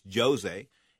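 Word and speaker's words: José: But Frankie Edgar came up short, a unanimous decision loser to Jose Aldo. It's spelled Jose, but it's Jose.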